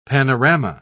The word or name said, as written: panorama